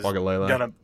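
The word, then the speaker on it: Uiagalelei: Because I think DJ Ugawuga is – Ogalele. – Ogalele, yeah, that's how you say his name.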